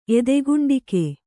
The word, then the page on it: ಎದೆಗುಂಡಿಗೆ: edeguṇḍige